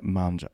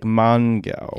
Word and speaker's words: Mongeau: Tana Mongeau. When you see it written down, you're like Mongo. – Yeah, it looks like Mongo. – But in fact, it's Mojo. Mongeau.